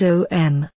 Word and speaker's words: Om: The pronunciation is OM.